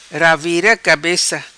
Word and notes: Ravira Cabeca: patua

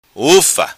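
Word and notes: Ufa: Macanese Patuá

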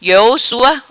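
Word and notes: Iou Sua: Patuá

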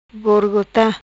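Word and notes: Gorogota: patua

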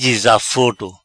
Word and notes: Dizaforo: Patua

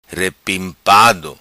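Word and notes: Repimpado: patua

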